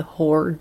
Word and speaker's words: hoar: podcast before, spelled H-O-A-R. It's a type of dew, the whore dew.